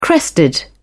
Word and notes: crested: British English

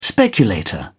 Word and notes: speculator: British English

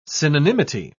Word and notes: synonymity: British English

